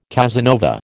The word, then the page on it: Casanova: American English